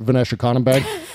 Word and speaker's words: Vnesheconombank: Vnesheconombank.